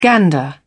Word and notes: gander: British English

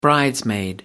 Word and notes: bridesmaid: American English

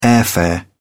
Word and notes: airfare: British English

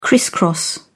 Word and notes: criss cross: British English

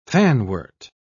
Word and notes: fanwort: British English

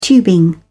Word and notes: tubing: British English

